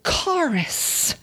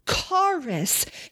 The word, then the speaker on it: charis: The Greek New Testament word for grace is charis. Charis. It's also sometimes pronounced charis.